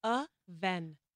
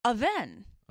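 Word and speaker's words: Avène: It's pronounced Avene. – Avene.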